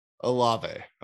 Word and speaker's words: Olave: is Olave or Olave? – Olave. – Olave. I knew I was saying it wrong.